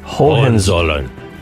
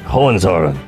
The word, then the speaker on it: Hohenzollern: Hohenzollern. – There you go. That's it. Hohenzollern.